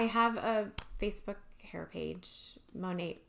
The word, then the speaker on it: Monat: So the name of the company is Monate, which stands for Modern Nature, which I think is really cool. It's spelled M-O-N-A-T, which it's funny.